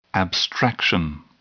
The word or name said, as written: abstraction